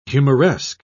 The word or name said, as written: humoresque